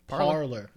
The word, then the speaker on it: Parler: P-A-R-L-E-R. No. How else would you pronounce it? Parler. There you go.